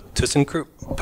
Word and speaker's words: ThyssenKrupp: But so ThyssenKrupp.